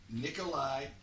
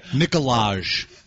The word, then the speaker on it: Nikolaj: Nikolai. – No, there's going to be a hard. There's going to be a hard J. – Okay, Nikolaj. – Right. – Yeah, it's going to be Nikolaj.